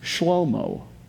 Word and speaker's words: Shlomo: But as far as we can tell, the way his name was originally pronounced is Shlomo.